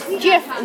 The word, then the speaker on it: GIF: but he claims that it is a soft G, so it should be pronounced as JIF instead of GIF.